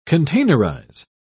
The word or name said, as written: containerize